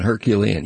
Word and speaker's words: Herculean: And I mean, it's a Herculean task, if I said that correctly. – Herculean. – Yes, it is.